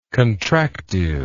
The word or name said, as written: contractive